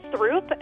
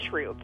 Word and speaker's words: Throop: Curious City listener always pronounced two Chicago streets the way they seemed to be spelled. But her parents, who grew up on the South Side, made fun of her. – I remember them laughing at me for saying Paulina and Throop instead of Paulina and Troop.